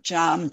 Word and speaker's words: Jan: I'm Jan, J-A-N, and the surname is C-A-V-E-L-L-E, Cavell.